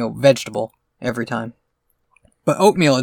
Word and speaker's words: Oatmeel: Yeah, but like Oatmeal, it's funny because they spell it O-A-T-M-E-E-L. So looking at it, it looks like a different word. But you say it out loud and it's just Oatmeal.